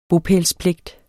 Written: bopælspligt

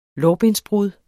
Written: lårbensbrud

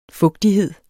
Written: fugtighed

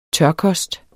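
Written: tørkost

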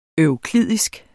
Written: euklidisk

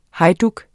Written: hejduk

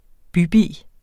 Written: bybi

